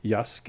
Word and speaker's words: Yasuke: And it's a project from creator and director LaShawn Thomas and the Japanese animation studios MAPPA, or M-A-P-P-A, executive produced by Lakeith Stanfield, who voiced Yasuke.